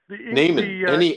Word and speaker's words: nehmen: The word for name in German is Name, N-A-M-E. It's the same as English, just pronounced differently. – Okay, but you said the word Namen, to take. – Namen, N-E-H-M-E-N, N-E-H-M-E-N.